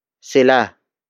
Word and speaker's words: Sila: The next word is sila. Sila. Sila. Sila is spelled as S-I-L-A.